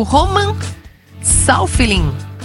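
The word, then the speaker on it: Safiullin: João Fonseca perde para o russo Roman Saufilin. É assim a pronúncia, Felipe Bueno? – Ah, eu estudei russo muitos anos da minha vida e chancelo a sua pronúncia.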